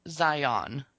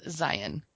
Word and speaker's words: Zion: Okay, people, it's not Zion. It's Zion. – Yep. – Everyone pronounces it Zion. Except those of us who live in Utah. – Who some say we actually live in Zion. – It's Zion. – It is Zion. – And maybe we're the ones saying it wrong, but we've got the park. That's how we pronounce it.